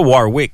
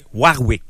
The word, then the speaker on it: Warwick: C'est pas Warwick. C'est Warwick. – Warwick. – Il faut le prononcer à la française.